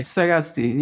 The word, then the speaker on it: Sagasti: Sagasti.